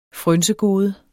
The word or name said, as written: frynsegode